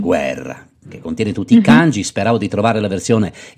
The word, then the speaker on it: kanji: Ecco, non dobbiamo introdurre una parola che è sovente citata in questo libro, la parola, spero di pronunciarla bene, ganji, sì. – Kanji. – Ah, kanji. Kanji.